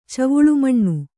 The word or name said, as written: ಚವುಳುಮಣ್ಣು